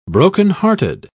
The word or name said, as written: brokenhearted